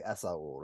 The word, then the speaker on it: Esaul: If you were going to say your own name personally, you'd say Esaul, right? – Esaul, yeah.